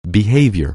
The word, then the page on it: behavior: anglais